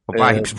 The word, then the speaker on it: Vives: Peter Vives. Peter Vives. – Vibes, eh, bueno, Peter pues, Vibes, eh, si se pronuncia así.